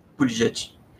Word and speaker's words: Buttigieg: Here's a pronunciation. This is what they use for his campaign. That's how it's pronounced. – Buttigieg.